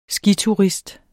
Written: skiturist

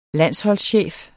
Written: landsholdschef

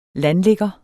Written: landligger

